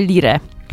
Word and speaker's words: Lyre: Ustaliliśmy, że Lir, czy Lyre. – Tak, Lyre. Naszą nazwę wymawiamy Lyre.